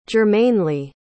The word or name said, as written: germanely